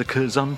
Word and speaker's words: Curzon: Curzon, which is the official way? – Curzon, okay. I'm going to be stuck with the Curzon from now on. – Silent O.